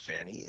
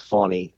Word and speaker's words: Fani: And in Georgia and in other states, and Fannie Willis, and by the way, it's Fannie, I've apologized for mispronouncing her name all this time, not Fannie, it's Fannie Willis.